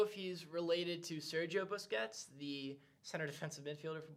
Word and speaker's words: Busquets: A player to watch out for Duquesne would be Manel Busquet. I hope I'm saying that right. – Manel Busquets.